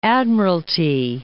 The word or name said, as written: Admiralty Inlet